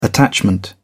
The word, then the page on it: attachment: British English